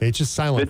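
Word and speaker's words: Hvidsten: Kyle Hovedstein, am I getting that right? – Vidston, H is silent. Vidston, thank you. – Like a Viking, yeah.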